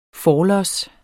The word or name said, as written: forlods